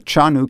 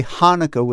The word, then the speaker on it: Hanukkah: So you can say Hanukkah with an H, but it's more Hanukkah. – It's really Hanukkah.